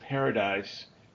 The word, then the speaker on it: Pardes: So there's a method called, if you pronounce it, Paradise, but it's spelled P-A-R-D-E-S, and each of the letters, actually, it's a Hebrew word, each of the letters are a different way to look at Scripture.